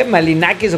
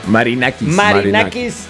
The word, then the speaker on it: Marinakis: ¿Malinakis o cómo? – Marinakis.